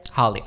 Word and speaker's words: Halle: Haley Bailey. How do you fucking, I can't. – Holly. – Oh, Holly. Like Holly. Yeah. She just spelled it wrong.